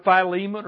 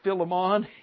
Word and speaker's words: Philemon: Titus chapter 2, right after the book of 2 Timothy, right before the book of Philemon or Philemon, either way you want to pronounce it.